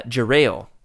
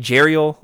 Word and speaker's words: Jerail: And so uh, uh, apparently, according to Skooma Joe, John Jackson Miller has gone on the record and saying that it's uh, Jerail. Oh. I was saying Jeriel, like the mermaid, you know.